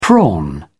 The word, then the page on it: prawn: British English